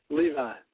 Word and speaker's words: Levi: Your middle name, is it pronounced Levi or Levy? – Levi.